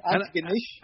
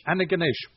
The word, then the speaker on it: Antigonish: Antigonish. – Antigonish.